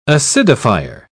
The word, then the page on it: acidifier: British English